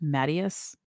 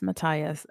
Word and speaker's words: Matthias: I have heard Matthias's name pronounced Mattias, which is not incorrect. That's actually the German pronunciation, but I'm going with the traditional anglicized, but once again, I'm going to state that you say things and it's how you say it.